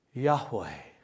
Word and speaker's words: Yahweh: You put syllables in between those letters. The Hebrew doesn't actually have syllables in the alphabet. Vowels in the alphabet. You put those vowel sounds in there, it can come out something like Yahweh.